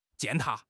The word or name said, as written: 践踏